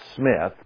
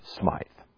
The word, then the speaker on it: Smythe: And most historians trace the beginning of the Baptist Church to a man by the name of John Smythe, S-M-Y-T-H. Now, I suppose it could be pronounced Smith, but most people with the Y in there pronounce it Smythe.